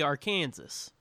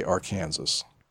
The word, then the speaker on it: Arkansas: They'll say Missouri and Arkansas, and it's like, it's clearly Arkansas. – Yeah, it is, but that's not how you say it.